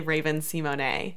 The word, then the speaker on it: Raven-Symoné: about how nobody says her name right, and it's actually Raven-Symone. – Yes, because it's French, and there's an accent. – Exactly. So technically, with that accent in there, she's not wrong. That is how it's pronounced.